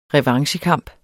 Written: revanchekamp

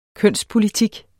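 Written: kønspolitik

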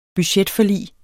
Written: budgetforlig

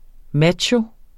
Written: macho

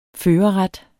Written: førerret

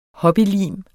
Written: hobbylim

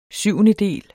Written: SYVENDEDELE

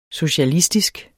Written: socialistisk

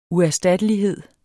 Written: uerstattelighed

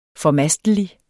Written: FORMASTELIGES

